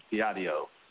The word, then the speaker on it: Viadeo: So they changed the name, and you actually pronounce it Viadeo. So it doesn't mean the way of God. It's like a viaduct, and that's actually where the name comes from, the bridging between people.